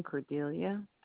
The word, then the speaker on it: Cordelia: First name, Cordelia. That's spelled C as in cat, O R D as in David, E L I A. Last name, Allen, A-L-L-E-N.